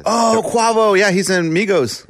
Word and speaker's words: Migos: Is it Migas or Migos? – Well, they're not. They're Migos. – They're dead. – Yeah, Migas is what you eat.